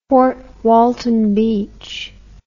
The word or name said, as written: Fort Walton Beach